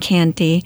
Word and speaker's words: Canty: If you go to Amazon and you look for Far Above Rubies and my name, Jolyn Canty, and it's spelled J-O-L-Y-N, and my last name is C-A-N-T-Y, like candy but with a T, not a D, and you'll find it.